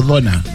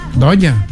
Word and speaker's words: Doña: ¿Se pronuncia Doña o Dona? Dona. – Doña. – Doña, porque en la radio están diciendo Dona.